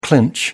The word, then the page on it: clinch: American English